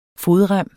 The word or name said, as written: FODREMME